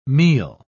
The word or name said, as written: meal